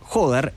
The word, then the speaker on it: Jódar: Se trata del señor Rafael Jódar, así se pronuncia, Rafael Jódar, el español, que es 11 del mundo con 19 años.